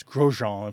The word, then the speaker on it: Grosjean: It's by François, I don't know if he pronounces his last name in French, but it's Grosjean in French, G-R-O-S-J-E-A-N. – Yes, I just looked it up.